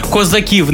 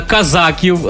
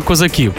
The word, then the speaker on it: козаків: Так, розповімо про найпоширеніші міфи про козаків, які ви могли е, знати, чути козаків, не е, казаків, е, як можна було і ще інше каверкати наголос, а саме козаків.